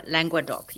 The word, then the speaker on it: Languedoc: Languedoc, yeah.